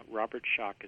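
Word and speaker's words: Robert Schoch: Some people like to misspell it because it's not exactly pronounced the way people think it should be spelled. – It sometimes sounds like there should be a K in there. But exactly, and it's not. It's just S-C-H-O-C-H.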